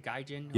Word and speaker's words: Gaijin: Is that how you say it? Gaijin? – Yep.